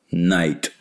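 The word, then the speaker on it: Knight: А постольку-поскольку «Night», оно пишется с буквой «K», но читается как «Night», как «Ночь».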